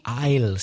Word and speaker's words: aisle: I prefer the aisle seat. – A-I-S-L-E. Be careful with the pronunciation. Aisle. The same pronunciation as I apostrophe double L. I will. Aisle and aisle. Exactly the same.